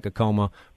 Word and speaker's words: Acoma: His people, the Acoma people, and I should point out to listeners, it's spelled A-C-O-M-A, so it looks like Acoma, pronounced Acoma.